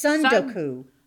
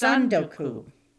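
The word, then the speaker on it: sundoku (積ん読): It's sundoku. It's sundoku. – The emphasis is on sun.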